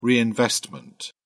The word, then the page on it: reinvestment: British English